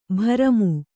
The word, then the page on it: ಭ್ರಮಿ: kannada